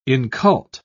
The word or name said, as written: incult